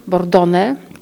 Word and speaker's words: Bordone: To jest scena mitologiczna namalowana przez Parisa Bordon, Bordone, tak się, i, tak, i tak się to nazwisko wymawia.